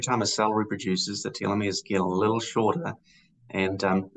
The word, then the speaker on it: telomeres: We've talked about telomeres, and I think I'm pronouncing them right. I think we want long telomeres, or do we want short telomeres? – Yeah, you got it.